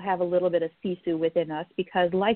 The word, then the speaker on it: Sisu: So let me talk about the word Sisu for a minute, because it may or may not be familiar to people listening. So Sisu is spelled S-I-S-U. It's actually a Finnish concept.